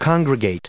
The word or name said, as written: congregate